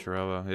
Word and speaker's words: Zuccarello: Zuccarello, yes. – And, yeah, Fenton basically went along the lines of saying that he that they went out and signed Zuccarello because he's basically like a lizard.